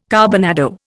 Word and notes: carbonado: Indian

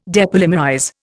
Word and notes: depolymerize: Indian

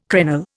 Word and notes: crenel: Indian

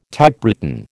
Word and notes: Typewritten: American English

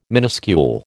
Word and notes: minuscule: American English

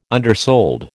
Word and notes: undersold: American English

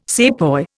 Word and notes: sepoy: Indian

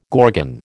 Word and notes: gorgon: American English

